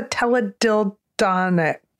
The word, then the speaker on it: teledildonic: What is a teledildonic? Did I say that right? Is that a teledildo? – Yes, absolutely.